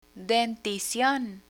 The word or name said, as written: denticion